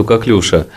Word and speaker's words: коклюш: Да, по поводу коклюша. Коклюш или коклюшево. Значит, как учили меня на кафедре инфекционных заболеваний, правильно говорить коклюш.